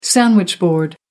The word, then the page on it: sandwich-board: American English